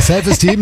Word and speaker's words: Self-Esteem: The Offspring, Self Eastern. – Ja, die Aussprache ist ein bisschen... – Steam ist Steam. – Ja. Ja, ist richtig. – Ja, Self-Esteem nehmen wir auch.